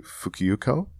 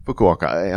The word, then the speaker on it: Fukuoka: Fukuoka. Fukuoka.